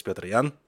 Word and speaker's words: Petr Yan: But obviously, the correct pronunciation is Petr Jan, and you're going to hear it a bunch as well, so bear with me.